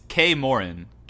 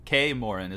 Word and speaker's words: Kaer Morhen: It's Kaer Morhen. Kaer Morhen is how you pronounce it.